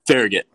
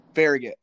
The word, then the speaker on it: Farragut: Farragut. – Farragut.